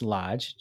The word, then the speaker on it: Loge: The next one, the Lodge Hotel, which is spelled L-O-G-E, but pronounced Lodge, just so you know.